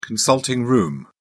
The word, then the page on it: consulting-room: British English